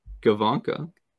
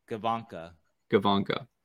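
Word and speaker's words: Gawanke: We looked this up to pronounce it correctly. Leon Gavanka. Gavanka.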